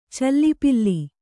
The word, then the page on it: ಚಲಪಿಲ್ಲಿ: cala pilli